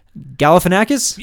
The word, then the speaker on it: Galifianakis: Let's see if I say it right today. Galifianakis? – Yes!